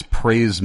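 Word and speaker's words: Pray's: That's Praise Mill Baptist Church of Douglasville, which is spelled P-R-A-Y apostrophe S, in Douglasville, Georgia, named after a man who started that church in his own barn, I believe, and his last name was Pray.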